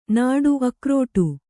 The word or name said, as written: ನಡು